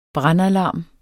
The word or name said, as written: brandalarm